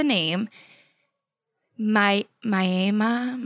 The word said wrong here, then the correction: Mamiya: My oh yeah, Mamiya. – Ma, Mamiya. Oh my gosh.